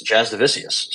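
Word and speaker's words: Jasudavicius: I've been saying just do the vicious. It's Jazz DeVicious.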